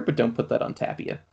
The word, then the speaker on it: Tapia: The only Rockies player I have is it Tapia? Is that how you pronounce it? Yeah. – Tapia. – Tapia.